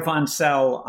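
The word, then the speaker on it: Wantzel: You mentioned that Pierre Vonsel, now I know how to pronounce it, Pierre Vonsel proved three of the four problems were impossible.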